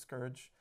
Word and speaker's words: Scourge: How's your band Crooks and Scrooge doing? Well, it's Scourge. It's spelled S-C-O-U-R-G-E.